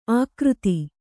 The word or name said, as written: ಆಕೃತಿ